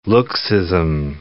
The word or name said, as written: looksism